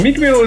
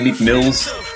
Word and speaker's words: Meek Mill: Meek Mill is one of those rappers where you're always wondering if you're saying his name right. It's almost like how a lot of people say Joe Budden, Joe Buttons. – Yeah. – For the longest time, I was saying Meek Mills,